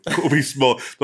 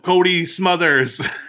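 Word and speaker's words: Cobie Smulders: I'm shocked that you just said her name right, too. Colby Smulders. The whole time we're watching the episode, he's like, oh, what about Cody Smothers?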